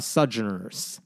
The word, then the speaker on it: Sojourners: Sojourners. – Sojourners? – Yeah, I'll spell that for you at some Sojourners? – Point. – Sojourners? Sojourners? Like Sojourner know. Truth? – Yes.